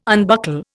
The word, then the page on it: unbuckle: Indian